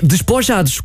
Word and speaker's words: despojados: Então é despojados? – É. – Ok, muito obrigado. – A sílaba tónica está no A.